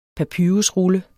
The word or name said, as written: papyrusrulle